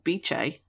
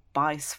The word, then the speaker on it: Bice: I mean, so she becomes a governess to Thomas Adolphus Trollope's 12-year-old daughter, Beatrice Trollope, which is pronounced Beche, or her nickname is Beche, which apparently is the Italian way of saying it. I was saying Bice for a long time until some really kind person put in the footnotes that it was pronounced Beche.